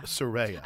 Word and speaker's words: Soraya: Soraya. – I always say, like, a ray of sunshine. – Oh, okay. – Soraya.